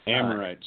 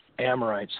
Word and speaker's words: Amorites: It ties so, clearly into the scriptures of the judgment on the, how do I say it? – Amorites. – Amorites. Thank you.